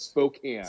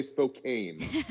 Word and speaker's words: Spokane: It's a can. Like, think about a soda can. It's Spokane. – Spokane. – Not, don't say Spokane.